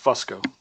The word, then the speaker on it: Fusco: no, but it's technically pronounced Fusco, but Fusco. Nobody ever says that.